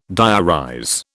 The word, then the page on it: diarize: British English